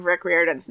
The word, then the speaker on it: Riordan: So, his name is pronounced Rick Riordan, according to Google.